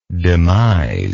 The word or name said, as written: demisable